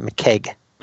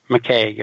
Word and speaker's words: McKeg: He got the win in that over Jason McKagey. That name probably sounds familiar. It's actually, they corrected me on the pronunciation there. It's Jason McKeg. – McKeg, okay. – McKeg. Yeah, so now we know.